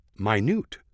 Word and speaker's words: minute: The proper word is pronounced minute, or in something very small, although it's spelled the same way as minute.